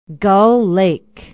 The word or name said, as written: gull lake